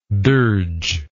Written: dirgeful

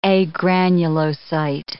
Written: agranulocyte

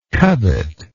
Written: covetingly

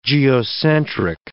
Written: geocentric

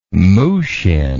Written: mushin

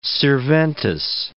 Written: sirventes

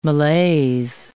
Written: malaise

